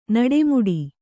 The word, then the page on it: ನಡೆಮುಡಿ: naḍe muḍi